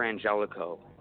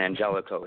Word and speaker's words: Angelico: All right, all you got to do is look up Thor Angelico, and that's spelled just like Thor. You know, it's not some superhero thing. It's my actual name, by the way. And Angelico, it's A-N-G-E-L-I-C-O.